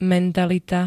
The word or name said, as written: mentalita